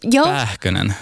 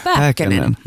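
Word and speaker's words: Pääkkönen: Och jag tänkte att vi måste prata om skådespelaren Jasper Pähkönen. Uttalar jag namnet rätt? – Pähkönen. – Pähkönen. – Mm. Ja. – Pähkönen. – Pähkönen. – Mm. Perfekt.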